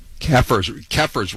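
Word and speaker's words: kafirs: Kaffirs rhymes with heifers.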